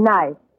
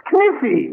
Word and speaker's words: knife: K-N-I-F-E. – K-N-I-F-E. That's Kniffy. – No, the word is knife. K is the silent letter. K is always silent in front of N.